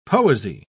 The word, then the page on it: poesy: British English